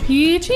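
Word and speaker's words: pici: Pronounced peachy. It's a four-letter word. It's a type of pasta.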